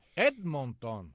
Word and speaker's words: Edmonton: Edmonton.